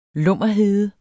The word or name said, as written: lummerhede